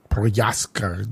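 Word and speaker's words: Procházka: Ó, tem um último breaking news aqui que o Jiri Prochaska, o cara até comentou como é que se fala o nome dele, é Prochaska, Jiri Prochaska, ah.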